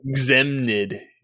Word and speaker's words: Gzemnid: yeah, Gzemnid,